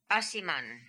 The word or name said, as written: Asiman